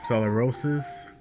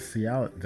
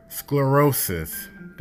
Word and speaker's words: sclerosis: Her father suffered from multiple sclerosis. I might be pronouncing it wrong. Is that Cialis? That's not Cialis. Sclerosis. Hold on. Sclerosis. That's how you pronounce it.